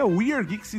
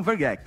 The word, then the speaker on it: WeRgeeks: se pronuncia Weird Geeks e não Vergex.